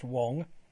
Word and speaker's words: Wang: although it's spelt W-A-N-G, is pronounced Wong.